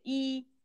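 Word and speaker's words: Lee: Bueno, en vez de Li se dice I.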